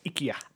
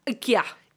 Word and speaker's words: IKEA: So, we've already spoken about IKEA, and it's pronounced IKEA. – IKEA. – IKEA. – I love it. – And I've written that down as IKI-YA. Icky. Yeah. – Oh, that's your... – That's my phonetic pronunciation.